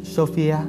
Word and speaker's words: Sophia: Sophie nhìn lại vào các chữ cái, cố đọc lên thành âm chuẩn. Sophia. Cô nghe thấy tiếng của chính mình. – và không thể tin vào cái mình vừa thốt ra. Sophia, chữ này phát âm như Sophia.